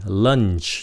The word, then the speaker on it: lunch: nó là for cái xong rồi lunch nhớ chờ nó nếu các bạn không có phát âm chờ nó không có giống lunch